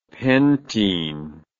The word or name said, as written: pentene